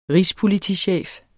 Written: rigspolitichef

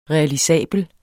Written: realisabel

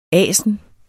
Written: ASNERNES

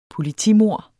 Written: politimord

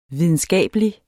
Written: videnskabelig

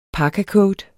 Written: parkacoat

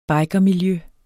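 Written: bikermiljøet

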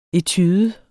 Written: etude

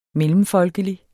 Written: mellemfolkelig

